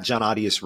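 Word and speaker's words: Jon Arias: I don't know if you can see my name on this podcast at all, but it's at John Arias Radio is my Twitter handle.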